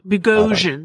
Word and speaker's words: Bogosian: Did I pronounce that correctly? – Like Bogosian? – Bogosian. Oh, there it is. Yep. That's right. I was like, nope, that didn't sound right. That sounds right.